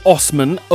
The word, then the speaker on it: Assman: Now, he pronounces his name as Osman, O-S-S-M-A-N, but it's spelt A-S-S-M-A-N, so that's his name.